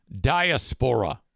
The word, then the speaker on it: diaspora: Diaspora. That's how I say it. Thank you for the pronunciation correction.